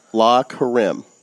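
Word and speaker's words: Le Carême: Tell me if I pronounce this wrong. La Karim. – I believe that's correct.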